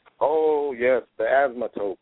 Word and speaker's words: asymptote: It's pronounced nothing like it's written, okay? – Right, exactly. – Because if you look at it, it says asymptote, right?